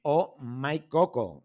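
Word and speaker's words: Oh My Koko: Nada, gracias a ti por atendernos, que vamos a aprender mucho contigo y con la experiencia que tenéis en Oh My Coco. ¿Lo he dicho bien? – Perfectamente, o como la interjección, ¿sí? – OH, my en inglés, mi y Coco, las dos con K, bueno, digo porque, bueno, para que la gente conozca un poquito nuestra marca, es una marca de cosmética natural y vegana.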